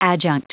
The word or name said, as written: adjunct